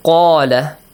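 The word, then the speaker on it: Qala (قال): Qala. In terms of recitation, make sure you should pronounce this word with an A sound. How do you read it? – Qala.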